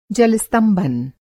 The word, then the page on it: जल-स्तंभन: Hindi